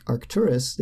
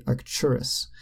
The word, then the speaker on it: Arcturus: Instead of saying a star like Arcturus, they can say Arcturus. And so the T becomes a ch, so it becomes Arcturus instead of Arcturus.